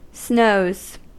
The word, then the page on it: snows: American English